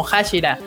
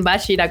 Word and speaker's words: Hashira: Entonces ellos se quedan con la idea de que así se pronuncia, o sea, que se lee como Hashira. Y de hecho hay dos, eh, bueno, uno en particular que es el de fuego, que se lee como en Bashira, como lo tienen aquí justamente.